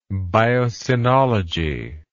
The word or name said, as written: biocenology